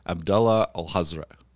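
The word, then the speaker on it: Abdullah al-Hazred: In the Arabic translations of Lovecraft today, it is written correctly, and he's like Abdullah al-Hazra, and that's it. Like that's how you pronounce it.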